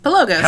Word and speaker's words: Pelagos: Did I say it right this time? – Yes, Pelagos.